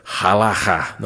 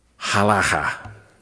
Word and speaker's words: halacha: This actually comes from the, let's see if I can say it right, halacha. No, halacha. There you go.